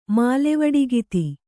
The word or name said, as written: ಮಾಲೆವಡಿಗಿತಿ